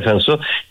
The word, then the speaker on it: Cinsault: Por ejemplo, hay cepas como el Cinsault, que se escribe Cinsault, se pronuncia Cinsault,